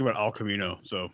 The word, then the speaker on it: Cimino: Oh, so it's pronounced Camino. I just had to look. I feel like I thought I heard it say that before, but I wanted to make sure. Yeah, Michael Cimino.